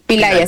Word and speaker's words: Pillay: Pillay, así se pronuncia, que ella fue jueza de la Corte Penal Internacional, entonces sí.